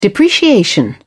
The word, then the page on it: depreciation: British English